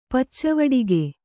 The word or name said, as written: ಪಚ್ಚವಡಿಗೆ